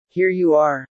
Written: Here you are

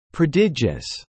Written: prodigious

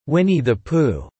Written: Winnie the Pooh

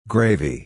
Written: gravy

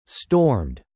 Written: stormed

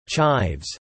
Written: Chives

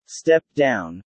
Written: step down

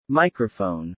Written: Microphone

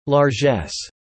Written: largess

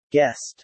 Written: guessed

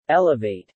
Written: elevate

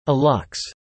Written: alux

